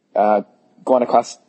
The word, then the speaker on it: Guanacaste: So Guanacaste, you know, am I saying it correctly? – Guanacaste. – Yep. Okay.